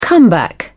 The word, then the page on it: comeback: British English